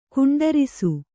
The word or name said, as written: ಖಂಡರಿಸು